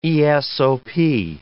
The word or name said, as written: ESOP